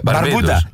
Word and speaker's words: Μπαρμπούντα: Και ήταν αγώνας μεταξύ, άκου αναμέτρηση, Αντίγκουα και Μπαρμπαντούα, Μπαρμπούντα και Μπαρμπούντα.